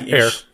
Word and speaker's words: heir: chooses an heir, usually, or a heir, how do you say that? – Yeah, heir. – Mute or not, yeah. – Silent age.